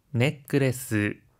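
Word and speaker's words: ネックレス: 하이, 넥크레스, 브레스렛도 라고 할 때는 중간에 초금이 들어가는데요. 한 박자 쉬었다가 발음하는 점 주의해 주세요.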